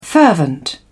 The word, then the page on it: fervent: British English